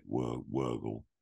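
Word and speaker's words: Wörgl: I probably got the pronunciation completely wrong, uh, but it's it's spelt Virgil. I think they say it Wurgle.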